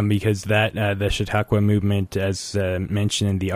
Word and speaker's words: Chautauqua: And this, the onset of the Glenwood Springs Community Concert Association in 1947 kind of came on the heels of the Chautauqua movement.